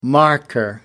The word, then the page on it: marker: American English